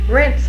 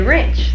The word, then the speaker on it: rinse: I used to say wrench, though. – That's gone, huh? – And didn't know I was saying it. – Oh, yeah, it's a good pronunciation. – Used to wrench the dishes. – Oh, And more, and there are a lot of people who say that, and they think they're saying rinse.